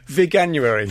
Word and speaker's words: Veganuary: Veganuary. – Oh, my God. Even I got the pronunciation wrong. Say that to me again.